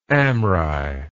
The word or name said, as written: Amri